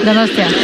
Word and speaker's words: Donostia: How do I say it? Donostia. – Donostia.